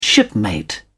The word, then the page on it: shipmate: British English